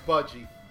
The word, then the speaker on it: Budgie: You mean Budgie. – Is it Budgie? Is that how you pronounce it? – It's Buggy. – The red Buggy bumper with the Buggy. Oh. – Beep, beep. – Herbie the Love Bug. Yeah, it's Budgie.